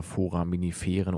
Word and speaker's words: Foraminiferen: Vorlese auf 93,0 und wir sind mittendrin im Thema des heutigen Abends, nämlich das Thema Foraminiferen oder Forams. Richtig ausgesprochen? Ja.